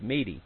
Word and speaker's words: Meda: Now, my grandma's name was Meda, M-E-D-A, but it was pronounced Meedy.